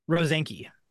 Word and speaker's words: Rosenske: Am I saying that right? – That's pretty close. Close enough. Yep. – Well, I don't like, what is it? – Rosenske. – Rosenske. Okay, perfect.